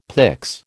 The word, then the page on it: pyx: American English